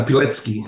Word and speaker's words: Pilecki: When did you learn about Captain, and I'm going to pronounce it wrong here, Pilecki in World War II? When did you find out about what he did? – Captain Pilecki.